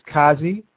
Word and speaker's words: Kazi: Yes, my name is Kazi. That's spelled K-A-Z-I.